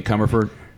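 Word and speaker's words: Commerford: Yeah, because Tim Crawford... – You mean Comerford? – I don't know how to say his name.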